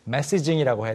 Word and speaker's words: messaging: Messaging이라고 해야 되는데 messenger처럼 messaging처럼 살짝 됐죠.